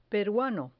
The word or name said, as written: peruano